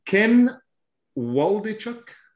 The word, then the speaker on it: Ken Waldichuk: I'm going to move on to, can you help me with this pronunciation? Ken Waldichuk. – Like that.